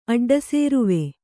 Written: ಅಡ್ಡಸೇರುವೆ

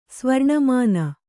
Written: ಸ್ವರ್ಣಮಾನ

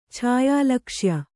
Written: ಛಾಯಾಲಕ್ಷ್ಯ

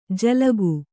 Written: ಜಲೌಕೆ

